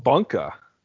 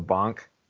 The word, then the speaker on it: Bunka: How about Bunka? I saw on Twitter you're supposed to pronounce it Bunk, like Bunk-a instead of Bonk.